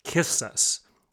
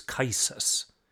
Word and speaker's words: Qisas: karma and what Islam calls Qisas. Another way to pronounce it is Qisas. It's spelled Q-I-S-A-S. Again, the proper pronunciation of it sounds like kisses. It's Qisas or Qisas, depending on the translation and the dialect. Now, what is Qisas or Qisas in Islam?